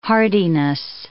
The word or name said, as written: hardiness